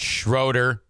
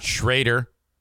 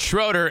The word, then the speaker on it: Schroeder: I double check, make sure her name is pronounced Schroeder, not Schrader. She says, "It's Schroeder, and thank you for asking."